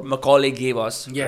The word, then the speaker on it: Macaulay: Am I pronouncing the word Macaulay yes, rightly? Yes, yes.